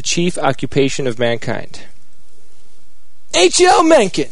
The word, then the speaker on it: Mencken: Yes, Mencken.